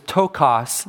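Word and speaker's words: ptochos: but the word that is chosen here is tokos. There's a silent P at the beginning of it, or a subtle P at the beginning, like ptarmigan.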